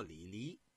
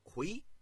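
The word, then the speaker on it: 李悝: 刚才啊，咱们说了一个人物，我说的呀、叫李黎，其实啊，这是一个多音字，这个呢是个多音字，也念啊魁，平声。念这个李呀、是这个三声，应该呀、在这人名里头念魁。这个李魁呀、他是嬴姓李氏，是战国时期的魏国人。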